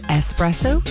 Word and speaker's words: espresso: Espresso. There's no X in it. It's espresso. Some of the other words in there, similarly.